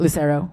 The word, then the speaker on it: Lucero: Lucero, is that how you say it? – Lucero. – Lucero.